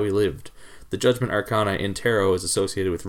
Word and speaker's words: Arcana: Arcana? – Arcana is how I heard it pronounced.